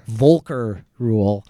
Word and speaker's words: Volcker Rule: Volcker Rule.